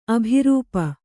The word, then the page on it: ಅಭಿರೂಪ: abhirūpa